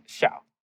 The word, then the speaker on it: Xiao: And then it wasn't until I met my wife, actually, and she's like, wait a second, that, that's not how your name is pronounced. And so from junior year onwards, um, I like, I would say, like, started pronouncing it the way that it was supposed to be pronounced, which is more like a Xiao.